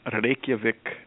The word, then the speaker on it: Reykjavik: I was in Reykjavik, Iceland on Friday, speaking in front of a very nice group in Sweden a few days before that as well.